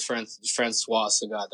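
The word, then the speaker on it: François Sagat: Oh, I would love to film with Francois Sagat. That's how you say his name, right? – Yeah.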